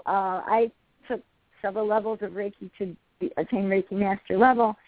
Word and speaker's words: Reiki: It's hard to know. It's Reiki. Two Japanese characters. It's a Japanese word. R-E-I-K-I. I remember Reiki. – It, how to pronounce it. I think of rays or light, and actually my first teacher was named Ray Golden, so that was I connected his name with the pronunciation.